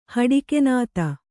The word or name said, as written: ಹಡಕುನಾತ